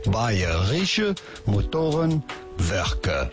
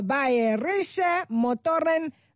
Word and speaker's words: Bayerische Motorenwerke: Bayerische Motorenwerke. – Ah, Bayerische Motorenwerke. – Werke. Werke. Bayerische Motorenwerke.